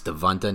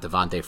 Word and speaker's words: Devonta: And you are a clear believer in it's Devonta, not Devontae Freeman, though everyone knows that one is also incorrect.